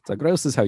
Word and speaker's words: Zagreus: like Zagreus is how you actually pronounce it.